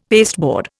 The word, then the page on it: pasteboard: Indian